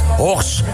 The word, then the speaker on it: Horst: Het weekend is van ons, ja, vanuit Horst. Zo moeten we het volgens mij uitspreken. Want naar goed Horsters dialect is het een stille thee op het einde.